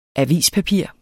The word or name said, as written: avispapir